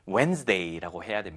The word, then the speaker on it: Wednesday: On Wednesday. 이거 수요일, Wednesday 아니고요. 물론 저도 이 단어 스펠링을 외울 때 예전에 학창시절에 많이 헷갈려서 Wednesday라고 외우면서 발음은 Wednesday라고 해야 됩니다.